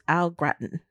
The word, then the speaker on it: au gratin: Oh, some potatoes en gratin. How you say it? En gratin? – Potatoes au gratin.